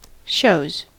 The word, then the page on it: shows: American English